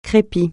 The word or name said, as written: crépi